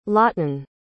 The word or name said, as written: laughton